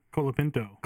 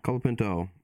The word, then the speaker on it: Colapinto: Colapinto. – Colapinto. Our bonus points are between Colapinto and Bierman. Who finishes better? You know what I mean? So Tyler's going Colapinto.